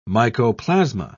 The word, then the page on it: mycoplasma: British English